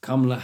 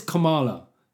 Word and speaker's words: Kamala: Well, let me tell you, like, his biggest problem, him and Kamala Harris, because apparently Brits aren't saying it right. We've got Kamala Harris. It says Kamala, for fuck's sake.